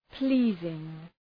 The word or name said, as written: pleasing